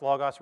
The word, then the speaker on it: logos: We're told that Christ came, that in the beginning was the Word, and that word, logos, it's pronounced logos, refers to Christ.